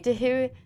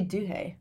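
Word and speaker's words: Duhé: I think that's how you say her name. – What's he? – Dehuey. – I'd say Duhay.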